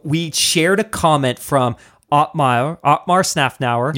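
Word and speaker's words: Otmar Szafnauer: Otmar Snafnauer.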